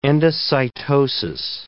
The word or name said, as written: endocytosis